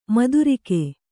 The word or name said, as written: ಮಧುರಿಕೆ